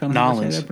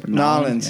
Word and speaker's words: New Orleans: Currently, she is, as we speak, in Oklahoma, and then in the next day, she will be in New Orleans. – Ah, excellent. – I don't know how to say that right. New Orleans, it depends on who you ask. – Nolens. – Nolens.